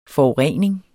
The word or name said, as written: forurening